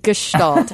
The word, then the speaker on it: Gestalt: But it is Gestalt. Hard G, S-H-E sound.